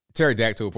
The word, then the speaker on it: pterodactyl: Pterodactyl, the P is silent.